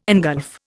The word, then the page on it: engulf: Indian